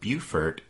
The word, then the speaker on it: Beaufort: But anyway, Reynolds arrived at the town of Beaufort in December 1861. – And thanks to listener Bill C. for letting us know that in South Carolina, B-E-A-U-F-O-R-T is pronounced Beaufort.